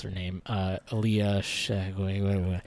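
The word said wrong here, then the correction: Shawkat: Oh, Shakya.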